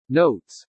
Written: NOTES

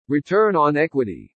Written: RETURN ON EQUITY

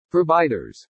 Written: PROVIDERS

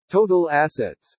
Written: TOTAL ASSETS

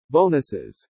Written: BONUSES

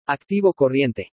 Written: ACTIVO CORRIENTE

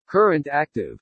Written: CURRENT ACTIVE

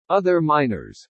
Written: OTHER MINORS

